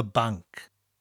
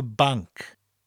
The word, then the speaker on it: banc: And now we need the word for bank. Very simple, it's spelt B-A-N-C and pronounced the same as the English.